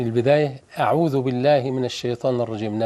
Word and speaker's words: الرجيم: نبهنا هذه في كل حلقات نرق الهمزة من كلمة أعوذ ومن بين الذال، وكذلك الطاء، أعوذ بالله من الشيطان الرجيم، ونكسر النون بينها كده، الرجيم. البعض يخطئ الرجيم أو يغنها الرجيم، أو يجيب ياء قبل الميم الرجيم، كل دي أخطاء.